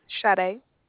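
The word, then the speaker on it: Sade: Shade. – Could you spell that for me? – Yes, it's S-A-D-E.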